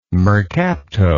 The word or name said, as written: mercapto